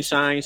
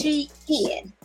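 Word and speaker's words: Shein: Thank you, Sheen, Shine, Shane, whatever the that is called. – On Twitter, they say it's Sheen.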